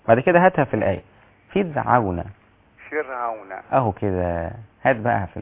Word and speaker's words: فرعون: فرعون. رقق الراء.